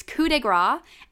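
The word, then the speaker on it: coup de grâce: But apparently, not only did I use it incorrectly, but I also said it wrong. So, two corrections here. It's coup de grace, and that apparently means final punch, which is not what I meant.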